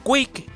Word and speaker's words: QUIC: Quick, que por cierto se escribe Q-U-I-C, ¿vale?